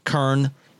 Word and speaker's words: Köln: That's that that's happening over there in Cologne, Germany, Köln, if you're German. I believe that's how they pronounce it.